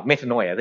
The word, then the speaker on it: metanoia: And again, this whole idea of repentance, the root word of repentance is called, and correct me if I'm wrong, Andrew, it's called metanoia. Is that how you pronounce it? Oh, metanoia. There you go, you see. That's why you have a trained theologian here.